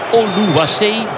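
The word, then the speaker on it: Oluwaseyi: De kopbal van Oluwasei. Moeilijke naam, maar zo moet ik het juist uitspreken. Tani Oluwasei.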